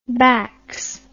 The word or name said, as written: backs